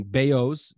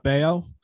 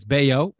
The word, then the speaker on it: Bello: Wednesday night was Brian Bello's debut, the big stud. – How is it not Bello? – B-E-L-L-O. – That's what I was saying. – And it's pronounced Brian Scott Bayo.